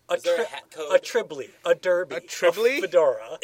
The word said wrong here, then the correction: trilby: A trilby.